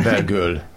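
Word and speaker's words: Bellegueule: Hogy kell kiejteni? Bocsánat. – Bergöl. – Bergöl. – Bergöl, mint szép pofa.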